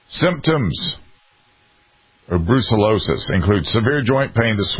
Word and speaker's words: brucellosis: They carry something called brucellosis, a bacterial infection. Is that how you pronounce it? – They do, exactly.